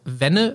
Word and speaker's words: Venne: Der ist bei seinem Heimatverein TSV Wenne, wird es hoffentlich ausgesprochen, V-E-N-N-E.